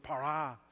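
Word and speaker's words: bara: This word that's used, that in created, it's the Hebrew word para. David, did I pronounce that right? Close enough. Thank you, brother.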